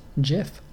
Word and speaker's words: GIF: The creator of the GIF says that it's pronounced GIF, like the peanut butter.